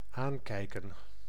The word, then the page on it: aankijken: /ˈaːŋˌkɛi̯kə(n)/